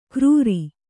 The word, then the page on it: ಗರೂರಿ: garūri; Kannada